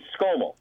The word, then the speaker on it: Skomal: Yeah, it's Skomal.